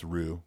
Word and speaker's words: Roux: How do you say that? It's R-O-U-X. – I think it's Roux.